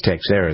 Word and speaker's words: Teixeira: But it is Teixeira.